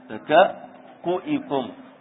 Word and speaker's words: تكأكؤكم: كيف تنطق؟ الكاف، الكاف مضمومة، الكاف مضمومة. تكأكؤكم، تكأكؤ يعني هكذا، هو التكأكؤ أصل الكلمة، التكأكؤ، فهذا تكأكؤكم فهو مضموم.